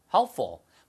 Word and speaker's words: helpful: 자, 그래서 what kinds of foods are helpful? 발음도 이게 쉽지 않습니다. helpful, helpful인데 들릴 말하면 helpful, helpful 이렇게 하고 fall만 크게 하시면 돼요.